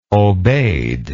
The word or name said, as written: obeid